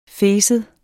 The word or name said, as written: fes